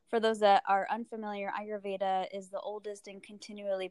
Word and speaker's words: Ayurveda: Yes, Ayurveda. – Ayurveda. – So it's like your I, your Veda.